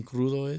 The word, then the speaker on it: crudo: Do you know what being crudo is? – Crudo? – Crudo, yeah. – Crudo. – Crudo. Crudo. It's spelled crudo.